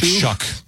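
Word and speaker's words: Shough: Is it Slough? Shuff? – It's like Shuck, even though it doesn't look like it.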